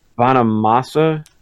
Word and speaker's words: Bonamassa: It's like, did you see that thing about with John Bonamassa? – Yeah.